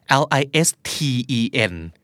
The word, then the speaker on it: listen: listen L I S T E N ออกเสียงไม่เหมือนมีทีเลยสักนิดเดียวคือ listen